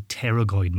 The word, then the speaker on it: pterygoid: These are the pterygoid muscles, spelt with a silent P, as in the flying dinosaur-like creature, the pterodactyl.